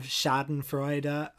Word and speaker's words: schadenfreude: So there was just a lot of schadenfreude. I've never said that word. Is that how you pronounce it? – Yeah, sure.